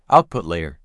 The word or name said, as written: Output layer